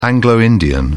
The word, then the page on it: anglo-indian: British English